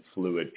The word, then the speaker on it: Phluid: And it's spelled P-H-L-U-I-D. – Yeah, P-H-P-H is the balance.